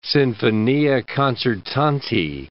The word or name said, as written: sinfonia concertante